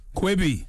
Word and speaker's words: Quibi: They have not announced a date, but they said it'll be available only on, now let me pronounce this right, Quibi? – Quibi.